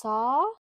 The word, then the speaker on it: saag: I got I believe it's pronounced saag curry, SAAG, I believe is how it's spelt.